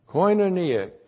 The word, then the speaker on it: koinonia: Koinonia, K-O-I-N, long O-N-I-A, koinonia.